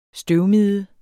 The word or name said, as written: støvmide